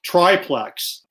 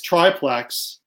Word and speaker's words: triplex: Neither she nor Woody Allen knew the proper pronunciation of the word triplex. And after she did two takes and she pronounced it triplex, and Allen told her to find the right pronunciation, she asked Donald Trump. And Donald Trump said, it's triplex. And then that's how she pronounced it.